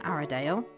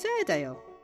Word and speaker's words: Airedale: Hope got her pronunciation wrong again. It isn't Aradale, it's Airedale. But Hope is right that the environment is in trouble.